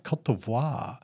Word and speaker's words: Côte d'Ivoire: Côte d'Ivoire.